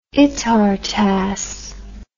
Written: itartass

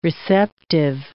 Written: receptive

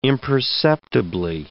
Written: imperceptibly

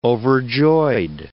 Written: overjoyed

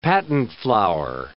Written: patent flour

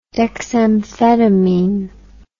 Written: dexamphetamine

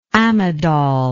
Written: amidol

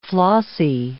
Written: flossy

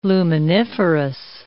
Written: luminiferous

